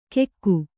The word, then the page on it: ಕೆಕ್ಕು: Kannada